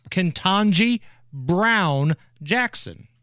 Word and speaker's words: Ketanji Brown Jackson: Ketanji Brown Jackson. – Ketanji Brown Jackson.